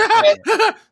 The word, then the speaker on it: Hartnett: It's pronounced Hartnett.